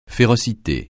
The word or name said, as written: férocité